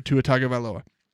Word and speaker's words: Tua Tagovailoa: There are no longer any excuses for Tua Tagovailoa.